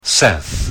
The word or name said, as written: Seth